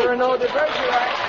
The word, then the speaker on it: Cyrano de Bergerac: I think it's Cyrano de Bergerac or something. – Cyrano de Bergerac.